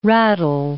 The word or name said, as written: rattle